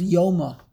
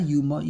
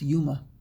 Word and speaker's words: Yoma: he says they have proof that the correct pronunciation is Yuma. In Aramaic, it's not Yom, it's Yuma.